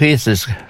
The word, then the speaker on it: Jesus: Och när förbundskaptenen George Jesus, eller Jesus ska det väl uttalas, på onsdagen meddelade att Ronaldo inte skulle starta mot Danmark, då valde stjärnan att lämna samlingen.